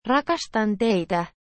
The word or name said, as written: rakastan teitae